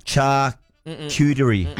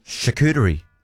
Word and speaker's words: charcuterie: Okay, let's slow it down then. Charcuterie. Charcuterie. – Yeah! – Yes.